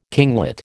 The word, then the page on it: kinglet: American English